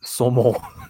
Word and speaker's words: saumon: Salmon in French is saumon. – Saumon. – It's just saumon. You substitute the L for a U. – Yeah. – Leaping like a saumon. – Saumon. Saumon is the pronunciation.